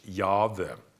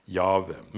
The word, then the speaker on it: Jahve: Og det, de fleste teologiske forskere mener at det skal uttales Jahve. Jahve. Men man vet ikke helt 100% sikkert.